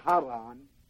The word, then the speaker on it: Haran: And this word spelled C-H-A-R-A-N, you know, charan, is pronounced, it's the word haran in the Old Testament, H-A-R-A-N, same word, same deal.